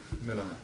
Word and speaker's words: Millar: How's it correctly pronounced? – Miller. David Miller. – Yeah, I guess we would just do it with an E. – It's pronounced as if it's an E, but it's an A. It's a Scottish Miller.